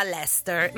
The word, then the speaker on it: Leicester: E poi ho scoperto dopo tempo, tra l'altro, che era Leicester. Ma nessuno sa perché si legga così.